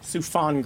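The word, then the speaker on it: Soufan: SUFAN, did I say that right? – SUFAN Group.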